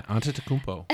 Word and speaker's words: Antetokounmpo: Yeah, Antetokounmpo.